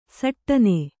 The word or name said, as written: ಸಟ್ಟನೆ